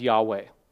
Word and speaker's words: Yahweh: The Holy Tetragrammaton, that is the name of God in the Bible that we pronounce as Yahweh.